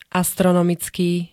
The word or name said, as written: astronomicky